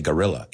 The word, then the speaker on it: guerrilla: Guerrilla, una palabra española, porque es guerrilla, but we say guerrilla, igual que gorilla.